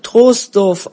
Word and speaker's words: Troisdorf: Troisdorf wird Troisdorf ausgesprochen, das I wird nicht gesprochen.